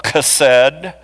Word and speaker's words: Chesed: I'm not Jewish, so I'm not going to pretend to speak Yiddish or any of the other, but it's sometimes it's spelled with a CH, and that's because it's actually kind of pronounced with that guttural Chesed. And so I'm just going to use the phrase Hesed, covenant love.